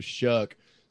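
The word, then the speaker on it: Shough: I mean, we on our podcast, it took us like five episodes to finally figure out that it was Shuck.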